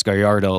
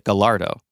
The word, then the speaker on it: Gallardo: But in the Spanish context of what the name is for Gallardo, you would say it as Gallardo, like a Y instead of the double L, right? But what the journalist found out is that the Italian test driver actually pronounced it Gallardo.